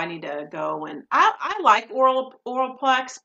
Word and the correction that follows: Olaplex: Oh, am I saying it wrong? Olaplex. Say it again. Olaplex.